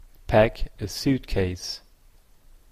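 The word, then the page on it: pack-a-suitcase: Canadian English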